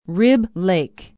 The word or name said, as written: rib lake